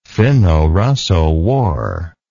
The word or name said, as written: finno russo war